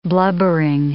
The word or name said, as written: blubbering